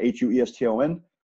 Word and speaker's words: Hueston: You know where you can find me on uh, Instagram at Coach Phil Hueston, H U E S T O N on Twitter at Phil Hueston, again, spelled the same way, H U E S T O N.